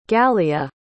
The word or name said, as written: galia